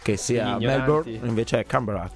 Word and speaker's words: Canberra: Sono stato a Canberra, che insomma la pronuncia, come ben vi piace notare, è Canberra.